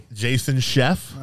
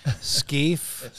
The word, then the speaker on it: Scheff: You pronounced that right. A lot of people, I've been called all kinds of things. Skeef, Skeff, Chef. You win the prize, buddy.